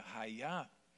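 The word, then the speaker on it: raiá (חיה): Então, essa palavra aí no hebraico, é, a, a, pronúncia dela seria raiá.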